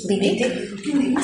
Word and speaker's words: Wittig: Cuando era más joven, Monique Wittig, ¿cómo se pronuncia? ¿Wittig? – Wittig. – Sí.